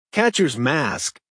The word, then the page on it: catchers mask: American English